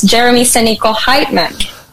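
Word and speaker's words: Jeramie Sanico Heitman: Well, I was known to Jeremie Sanico, now it's Jeremie Sanico Heitmann.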